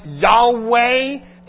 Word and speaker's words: Yahweh: It is the I Am, probably pronounced something like Yahweh.